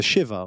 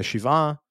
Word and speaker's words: shiva: In the Shiva, notice how I did the American Jewish pronunciation and then the correct Hebrew pronunciation of Shiva, which literally means seven.